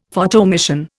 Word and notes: Photoemission: Indian

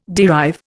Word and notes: derive: Indian

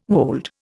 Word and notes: wold: Indian

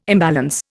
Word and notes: imbalance: Indian

